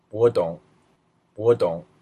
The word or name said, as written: dong